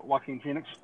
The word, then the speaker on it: Joaquin Phoenix: I honestly think, how you say his name, Joaquin Phoenix or whatever. – Yeah, Joaquin Phoenix.